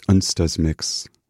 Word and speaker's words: Onsdagsmix: And just before starting this program, I had a bit of anxiety about how to pronounce the name of the program. So I grabbed my notebook and I wrote it down. It's Onsdagsmix, in case you're just tuning in.